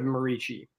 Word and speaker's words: Merici: Is it Mercy Village or the Village of Mercy? – The Village of Merici. So Merici.